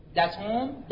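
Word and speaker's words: datum: دیتون یا داد تلفظ لاتینش داتون میشه همون داده.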